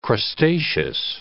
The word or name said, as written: crustaceous